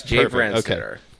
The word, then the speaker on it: Brandstetter: Yes, Jay Brandstetter.